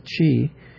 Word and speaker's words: qi: The symbol that looks like the Latin uppercase R, the Chinese Han, character for the Qi, is also used to represent a note in Gong Chi Pu, the Chinese musical notation. The Chinese word qi, with a downward vowel sound, also means the life breath.